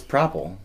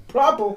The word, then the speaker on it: Propel: It's Propel. – Propel.